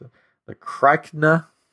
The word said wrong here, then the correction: Krykna: spider like creatures. Krakna. I don't know. It's Krykna.